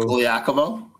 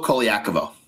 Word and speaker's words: Colaiacovo: How do you say his last name? Carlo Colaiacovo. Carlo Colaiacovo.